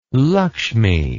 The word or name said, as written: Lakshmi